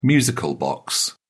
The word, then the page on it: music box: British English